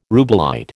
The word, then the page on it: rubellite: American English